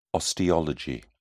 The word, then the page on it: osteology: British English